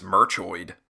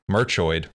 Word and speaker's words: Merchoid: What's the name that I just butchered? – What is yeah, that? I mean, you could have been on the money. I believe it's Merchoid. – Merchoid.